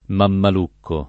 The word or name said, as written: mammalucco